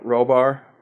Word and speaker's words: Rohrbaugh: Rohrbaugh forums.